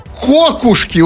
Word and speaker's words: кокушки: Кокушки, ударение на О.